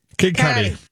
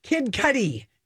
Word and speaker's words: Kid Cudi: How do I say his name? – Kid Cudi. – Kid Cudi. Cudi, like Cudi Sark.